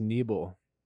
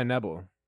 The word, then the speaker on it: Nebel: My last name is actually pronounced Neeble, but nobody goes by Neeble. – Is it Neeble? – It's Neeble. – It's Abel. – Why am I just finding this out now? Why did you never correct me ever? – Because we go by Nebel, like all of us. We just collectively over the past few hundred years are like, yeah, we just it's Nebel, pronounced Nebel.